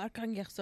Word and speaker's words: Arkhangelsk: She was born in a Russian village of Edma in, this is going to be a hard word to pronounce, Arkhangelsk. Oblast. – Oh, Arkhangelsk Oblast.